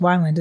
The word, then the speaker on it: Wyland: They also have the Screen Door General Store, Disney's Character Carnival, Thimbles and Threads, and one of our favorites, Wayland Galleries. – Right. – It's actually pronounced Wyland, isn't it? – I believe so.